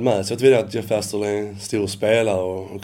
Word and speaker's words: Jeff Astle: Är det så man uttalar det? – Jeff Astle.